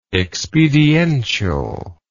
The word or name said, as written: expedientially